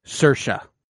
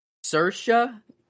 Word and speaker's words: Saoirse: That is not how you pronounce her name. It is Saoirse. Saoirse Ronan. – Saoirse? – Saoirse. – S? – She's what? Extremely Irish.